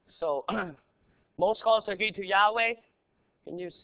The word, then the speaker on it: Yahweh: We pronounce it Yahweh, but it's only got four letters. It's all consonants. We put the vowels in there and we say Yahweh.